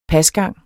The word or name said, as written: pasgang